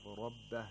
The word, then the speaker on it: ربه: The Ra of Rabbah has a fatha, so it has to be pronounced with thickness, as opposed to the word Zakariya, where the Ra was pronounced with thinness.